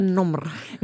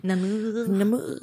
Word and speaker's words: Namur: Nomr. – Namur. – Jag tänker att jag tänker i mitt huvud uttalas det som lemur liksom.